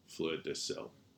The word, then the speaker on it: fleur de sel: F-L-E-U-R. This is three words. – Okay. – D-E-S-E-L. Fleur de sel.